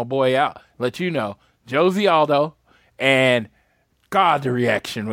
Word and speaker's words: Jose: No, and just to clear, it's Jose. I don't know why. I don't know why, but they pronounce the J in Brazil, so it's Jose Aldo.